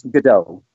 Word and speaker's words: Gadot: and the last name is Gadot, correct? I know I've heard everything from Gadot to Gadot. – Yeah, I think you pronounce the T a little bit.